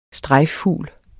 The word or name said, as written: strejffugl